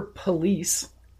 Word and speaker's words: Police: So speaking of needless suffering, a Florida cop named Christopher Police. – Police. – It's actually pronounced police, which I'm like, wow. – Oh, is it really? – Yeah, it's pronounced police.